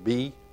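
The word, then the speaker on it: Bae: I think you pronounce his name B, B-A-E.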